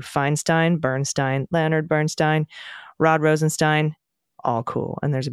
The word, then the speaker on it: Rosenstein: Rosenstein is pronounced Rosenstein. See also Diane Feinstein, Leonard Bernstein, etc.